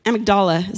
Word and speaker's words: amygdala: Here is a study that came out about worship, and I said it wrong. But amygdala, is that how you say it? Amygdala. I said it wrong again. Amygdala.